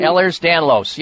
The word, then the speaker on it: Ehlers-Danlos: But he has had this for years, and they finally diagnosed him with, is it Ehlers-Danlos disease? I probably not pronounced it. – Ehlers-Danlos, yes.